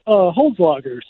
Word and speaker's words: Hole's Lager: Tell us a little bit about Hole's Lager. Hole's Lager. Am I saying that right? Tell it for me. – It's Hole's Lager.